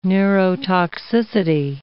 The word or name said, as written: neurotoxicity